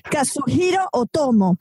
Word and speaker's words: Katsuhiro Otomo: Es Katsushiro, no, Kazuhiro Otomo, autor de manga japonés.